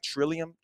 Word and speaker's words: Trillium: But so you've got am I saying it right? Trillium. – That's correct.